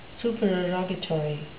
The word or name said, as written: supererogatory